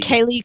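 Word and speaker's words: Kaley: Kelly Cuoco. Or is that how you say her name? I don't even know. From Big Bang Theory? – I thought it was Kaylee Cuoco. – Okay, Kaylee Cuoco.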